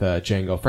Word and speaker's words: Django: Favorite thing is Franco Nero has an awesome cameo in this movie where he drinks a shot with Django. Franco Nero's the original Django, of course. And Django looks at him and says, "The D is silent," and he just looks back at him and goes, "I know," and then takes the shot.